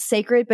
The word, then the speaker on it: Cécred: And by the way, it's called Cécred, but it's spelled C E C R E D, like Beyonce. Like Beyonce grid. Like say. So the foundation collection moisturizes,